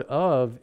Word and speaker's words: of: Now see, what the trick in that is that the word of is spelled O-F, but it's pronounced O-V, and so we don't see it.